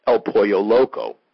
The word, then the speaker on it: El Pollo Loco: I remember my favorite Mexican restaurant, and now I know it's pronounced El Pollo Loco.